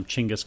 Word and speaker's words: Chinggis: So, Genghis Khan, I think we've all been like enamored with that name Genghis Khan, which we're both calling him Chinggis Khan too, because we did some reading and apparently that's the proper pronunciation.